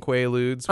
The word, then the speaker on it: Quaalude: Well, under a deposition, I believe, right, in 2005, he confessed to purchasing Quaaludes, which, first of all, a lot of people don't even know how to pronounce Quaaludes because it has like 4 U's right. – in a row in it. It's Quaalude.